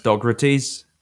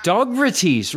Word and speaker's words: Dogrates: Everybody, you know, has this like long list of people they like, they don't like, they don't like Dog Race, they don't like whatever. And like, I'm yep, fine, I'm in tune with it. But also, it's pronounced Dogrates. – Dogrates, right, right.